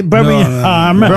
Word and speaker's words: Birmingham: On ne doit pas prononcer le G, hein, il paraît. – Birmingham.